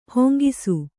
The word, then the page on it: ಹಾಂಗೆ: hānge